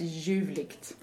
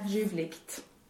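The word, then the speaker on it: ljuvligt: I wanted to hear whether you pronounce the G at the end of it before the T. So juvligt. – Almost like a K sound then. – Yeah, or a juvligt. – So it's just sort of a very fast G.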